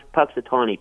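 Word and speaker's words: Punxsutawney: Oh, there's one thing about, Lynn, you did pronounce Puxatawny pretty good.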